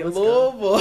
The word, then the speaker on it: Louisville: Am I saying that right? – Louisville. Yeah.